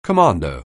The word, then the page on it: commando: anglais